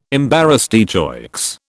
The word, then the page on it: embarras de choix: British English